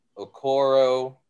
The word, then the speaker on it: Okoro: led by the likes of Okoro, I think. Dan, am I pronouncing that right? – You are.